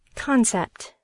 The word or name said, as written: concept